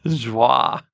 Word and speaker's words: Joie: It's Joy, J-O-I. – I would say Joy. But Wah. I love that little intonation. Say that again, your way. – Joie.